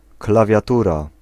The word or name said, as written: keyboard